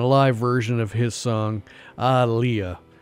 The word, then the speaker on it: Aaliyah: That one's always tough to pronounce in person because on the record it's spelled A-H, you know, exclamation point, Leah, exclamation point. Like you just discovered someone named Leah by surprise. Ah, Leah. But it's, I guess it's Aaliyah.